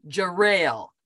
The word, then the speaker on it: Jarrell: I'm going to say this correctly, Jarrell. – Yes.